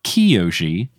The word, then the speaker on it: Kyoshi: and I've learned that we have been saying Avatar Kyoshi or Avatar Kiyoshi back and forth with no regularity, and shout out to the people on the Potterless Discord who let me know that it is properly pronounced Avatar Kiyoshi.